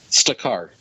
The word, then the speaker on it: Stakar: I was trying to understand how they say his name. Staker? – Stakar.